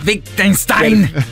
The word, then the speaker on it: Wittgenstein: Si pronuncia Wittgenstein. – Per chi conosce la filosofia, famoso, molto, molto famoso, molto famoso. – Ma, ma non si pronuncia Wittgenstein? – Io sono pessimo.